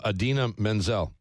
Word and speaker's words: Idina Menzel: Another Idina Menzel. Am I pronouncing her name right? – Yes.